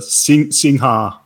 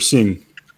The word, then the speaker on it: Singha: Well, the spelling is Singha, but when you're in Thailand, you can just say Sing.